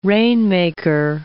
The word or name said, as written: rainmaker